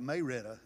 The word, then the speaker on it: Marietta: There's a city called Marietta. Here it's called Murrieta. We just call it Mayretta in Georgia.